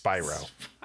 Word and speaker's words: Spyro: It's pronounced Spyro.